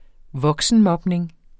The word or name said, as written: voksenmobning